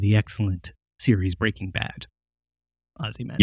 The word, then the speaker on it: Ozymandias: Yeah, Ozymandias.